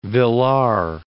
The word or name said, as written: Villars